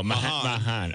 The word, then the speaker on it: Mahan: I will put Hunter, how is that pronounced? – What? – Oh, Mahan.